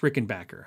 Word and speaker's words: Rickenbacker: It's pronounced Rickenbacker. I should point that out.